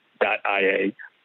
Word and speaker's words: Lögreglan: So the police in Iceland are called the Lögreglan, and I looked up how to pronounce that. So it's Lögreglan.ia is its official domain, and the attacker had registered Lögreglian.is, so an I versus an L.